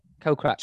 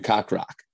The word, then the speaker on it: Kokrak: Kokrak. – Jason Kokrak. Did you see that the announcer got his name wrong? Called him Kakrak. – What did he call him? – Kakrak.